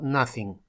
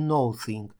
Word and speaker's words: nothing: То же самое касается слова nothing. Кстати, обратите внимание, хоть мы no сочетаем со словом think, но это не no thing. Это слово читается только как nothing.